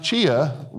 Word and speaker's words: CHEA: Dr. Judith Eaton is the president of the Council for Higher Education Accreditation, pronounced CHEA, which has always sort of amused me because of CHEA pets.